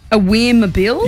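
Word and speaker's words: Awer Mabil: Now, I hope I'm saying this right. Aweer Mabil? – Yeah, that's how I would have pronounced it too.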